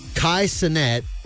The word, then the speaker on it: Kai Cenat: Kai Cenat, I think that's how you pronounce his name.